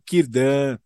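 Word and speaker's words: Círdan: Tá, é, isso, o início do, da letra C, o Tolkien, ele colocou a letra C como, como se fosse a letra K, ou como se fosse o Q e E. – Então é Celeborn, Kirdan, é Kelegorn, enfim, tem todo, toda, toda. – É só um é, ajuste de pronúncia.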